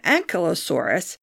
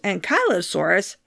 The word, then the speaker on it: Ankylosaurus: A lot of paleontologists pronounce it Ankylosaurus, but it's properly pronounced Ankylosaurus, and for once, I'm finding the correct pronunciation easier, probably because it has the name Kylo right in the middle, like Kylo Ren of Star Wars.